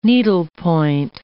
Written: needlepoint

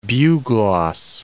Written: bugloss

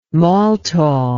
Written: maltol

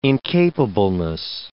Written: incapableness

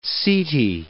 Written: setae

